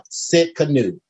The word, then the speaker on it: Tsidkenu: And that name, according to the names of God, is Jehovah Sikkenu. And it's spelled T-S-I-D-K-E-N-U.